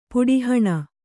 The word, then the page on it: ಪುಟ್ಟೇಹಣ್ಣು: puṭṭē haṇṇu